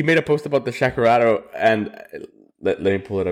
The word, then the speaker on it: Shakerato: And then recently Pull and Pour made a post about the Shakerato.